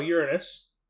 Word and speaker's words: Uranus: Uranus? – No, Uranus. That's how it's pronounced.